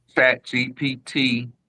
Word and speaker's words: ChatGPT: With AI, ChatGPT, am I saying that right? – Yeah.